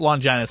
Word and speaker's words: Longinus: I think you're mispronouncing it. I think I'm mispronouncing it as well. – Longinus. – So it's okay. Linus. Okay, so it is. It's Longinus. – It's a Greek word. – Yeah, it's Longinus. It's pronounced Longinus.